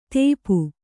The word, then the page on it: ತೇಪು: tēpu